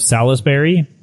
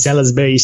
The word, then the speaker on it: Salisbury: Blood-curdling screams of the Countess of Salisbury. I believe that's how that's pronounced. You might be surprised. – Salisbury steak? – Pretty much the same thing, yes.